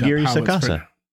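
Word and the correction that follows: Aguirre-Sacasa: Roll your R's more. – Aguirre Sakasa. – There you go.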